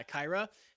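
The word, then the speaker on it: Kaira: I believe that's how you pronounce it. Kyra? K-A... – I'm pretty sure. – I-R-A. Yeah, Kyra headset.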